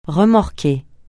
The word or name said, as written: remorquer